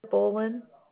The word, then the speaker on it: Bolin: Yes, Julia Bolin. – Can I have you spell that for me, please? – It's B-O-L-I-N.